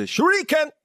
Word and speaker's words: shuriken: It's a shuriken.